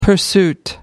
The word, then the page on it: pursuit: American English